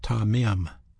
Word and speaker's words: tamim: That word sincerity is a Hebrew word pronounced tamim, tamim.